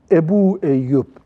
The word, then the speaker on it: Ebu Eyyub: Eyüp olarak biliniyor. Eyüp yanlış. Eyüp oğlunun adı. Kendi adı Eyüp değil. Ama Türkçe telaffuzda Ebu Eyüp demek zor olduğu için Eyüp diye kalmış öyle. Ama ne, kastedildiğini biz anlıyoruz. Yoksa o isim yanlış.